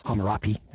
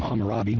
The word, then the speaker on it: Hammurabi: You might not know much about this guy, Hammurabi, or Hammurapi, as his name is spelled with P's instead of B's, but well, I've always been taught to call him Hammurabi with a B.